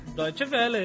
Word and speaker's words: Deutsche Welle: Bom, então, The Best of the Blogs é um prêmio organizado pelo Deutsche Welle. – Olha, é assim que se pronuncia? Deutsche Welle, é isso.